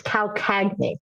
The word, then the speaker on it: Calcagni: It's Calcagni. So it's a Southern Italian name and very, very hard to pronounce.